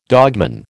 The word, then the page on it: dogman: American English